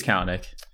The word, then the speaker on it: Kalanick: I, I don't know if I'm pronouncing the last name right, but Kalanick. Is it Kalanick? Yeah, um, or I Kalanick. Right. Um, it's it's Kalanick.